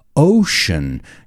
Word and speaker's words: ocean: ocean. Yo siempre decía océano. I always mixed that word up. Now I think I say it correctly. Océano? We say in English, ocean. It sounds like shh, shh.